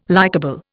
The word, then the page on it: likable: Indian